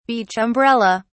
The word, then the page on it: beach umbrella: British English